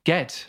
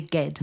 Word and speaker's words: Ged: Ged. Right, okay. Thank you for that. I skipped Anglo-Saxon at university. – I think it's actually, I read how to pronounce it, or I heard Ursula Le Guin doing a lecture or something, I can't remember. It's definitely Ged.